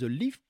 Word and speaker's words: LIF: Le nom du projet, c'est The Leaf Project Low Impact Fleece Jacket. J'ai bon sur la prononciation C'est ça, exactement.